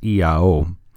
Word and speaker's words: IAO: I've read a little bit about EIO, and it says that it looks like the letters I-A-O, but it's pronounced EIO.